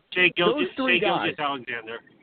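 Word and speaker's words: Shai Gilgeous-Alexander: Shea Gilgeous-Alexander.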